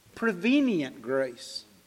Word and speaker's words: prevenient grace: Prevenient grace.